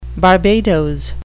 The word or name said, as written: Barbados